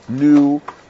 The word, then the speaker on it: gnu: How do we know that a yak is in the cow family for kashrut, and a wildebeest, which is also called a gnu, spelled with a silent G at the beginning.